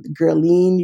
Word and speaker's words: Guerline: My name is Guerline, Telusma Emmanuel, Guerline, usually in English pronounced.